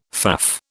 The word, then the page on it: faff: British English